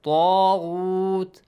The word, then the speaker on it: طَاغُوتِ: Alif mad, make sure you pronounce it with a heavy sound because it's a heavy letter. فَمَنْ يَكْفُرْ بِالطَّاغُوتِ طَاغُوتِ Hassan, say this.